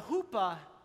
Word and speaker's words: chuppah: In Jewish weddings, they have what's called a huppah. And this is a huppah right here. We have another one on the other side. It's spelled C-H-U-P-P-A-H. But a huppah is something at every Jewish wedding.